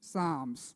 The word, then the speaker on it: Psalms: Now, it starts with a P. Say P. P. So it's spelled Psalms, but it says it's Englished Psalms.